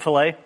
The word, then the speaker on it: fillet: This strip of fish can have a double L and a sounded T. McDonald's prefers a single L and silent T. – What's a fillet? – Yes.